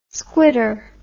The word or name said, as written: squitter